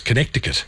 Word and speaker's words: Connecticut: actually has got a C in it. So if you're doing it phonetically, it's Connecticut.